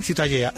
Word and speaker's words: Ciutadella: ¿cómo se pronuncia? – La catalán? – Ciutadella. – Ciutadella.